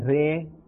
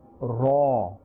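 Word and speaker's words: Ra (ر): We will not say Re or Ra, but a fuller and a thicker sound, Ra. We should open our mouth a little and pronounce with a fuller sound. Ra.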